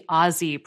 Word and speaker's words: Aussie: Australians themselves only use the Aussie pronunciation.